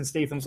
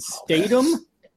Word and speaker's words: Statham: How do you say Jason Statham's last name? Is it Statham? – I think you're saying it the right way. – I'd say Statham.